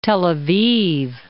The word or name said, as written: Tel Aviv